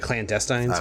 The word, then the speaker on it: Clandestines: And honestly, at this point, I mean, the clandestines, am I saying that right? – Yeah, the clandestines.